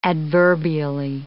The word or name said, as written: adverbially